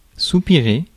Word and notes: soupirer: France; [su.pi.ʁe]

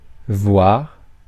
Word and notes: voire: France; [vwaʁ]

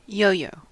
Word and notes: yo-yo: American English; [ˈjoʊ.joʊ]

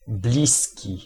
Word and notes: prochain: [pʁɔ.ʃɛ̃]; France

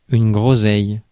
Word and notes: groseille: France; [ɡʁo.zɛj]

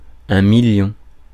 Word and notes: million: [mi.ljɔ̃]; France

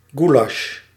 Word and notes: goulash: /ˈɡulɑʃ/; /ˈɣulɑʃ/

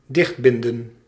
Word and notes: dichtbinden: /ˈdɪx(t)ˌbɪndə(n)/

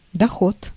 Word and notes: profit: /ˈprɒ.fət/; [ˈprɒ.fət]

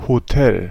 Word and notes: hôtel: /o.tɛl/

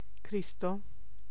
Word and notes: Cristo: /ˈkristo/; [ˈkriː.st̪o]

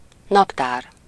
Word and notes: calendrier: France; [œ̃ ka.lɑ̃.dʁi.je]

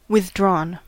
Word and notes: verschlossen: /fɛɐ̯ˈʃlɔsn̩/; /fɛɐ̯ˈʃlɔsən/

